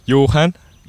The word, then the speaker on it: Johan: Johan. – Like John. – Yeah.